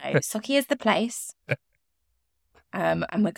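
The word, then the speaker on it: Sauchie: But I think it's Socky.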